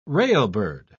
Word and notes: railbird: British English

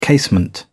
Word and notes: casement: British English

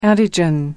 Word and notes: antigen: American English

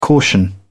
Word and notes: caution: British English

